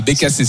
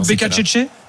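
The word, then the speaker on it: Beccacece: Bah, Becacese, c'est alors. – Plutôt Becacece ? – Non, ça ce serait italien.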